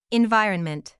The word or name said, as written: environment